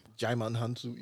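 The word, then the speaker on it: Djimon Hounsou: Jaimon Hansu. – Yeah. Wait, Silent D. – Yeah. – Like Django. – Like Django, yeah.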